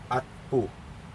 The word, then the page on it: atpu: Aneityum